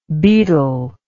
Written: baetyl

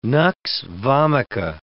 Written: nux vomica

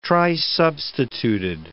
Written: trisubstituted

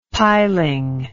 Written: piling

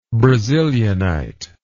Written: brazilianite

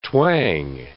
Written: twang

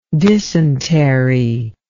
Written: dysenteric

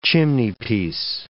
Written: chimneypiece

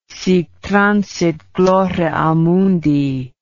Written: sic transit gloria mundi

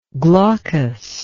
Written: glaucously

